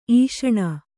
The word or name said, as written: ಈಷಣ